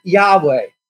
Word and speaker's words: Yahweh: The specific name that is applied to God, the God of the Bible in the Old Testament is, we don't know really how it's pronounced, but we pronounce it Yahweh.